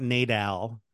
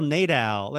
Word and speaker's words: Nadal: It's not Nadal. Rafael Nadal.